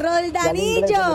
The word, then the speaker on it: Roldanillo: Y para Ronaldillo. Ronaldillo. No lo puedo pronunciar. – Roldanillo.